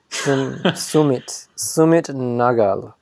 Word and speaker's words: Nagal: But is it Nagel? I actually don't know. – like Nagel's bagels? – Yeah. – Sumit Nagal. – Oh, yeah, that sounds right. – I think it's Nagal.